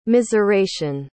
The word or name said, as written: miseration